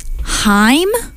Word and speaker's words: Haim: So our first song was "The Wire" by Haim.